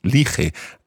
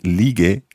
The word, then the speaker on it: ligue: Otherwise, it would be lige, and we can't have that. It needs to be the hard G sound.